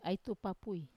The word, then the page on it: ai tupapui: Wamesa